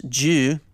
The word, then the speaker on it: jeu: It's also been suggested the name derives from the French jeu trompé, meaning toy trumpet, and French jeu, which sounds like Jew, with a soft J, Z-H, means to play.